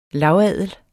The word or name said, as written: lavadel